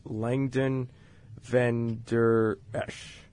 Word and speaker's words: Leighton Vander Esch: He has done everything pretty much on that defense, and he is, pardon me for the pronunciation, is Langdon Van Der Esch, if I'm saying that correctly. – Yeah, you said that. Yes. Right.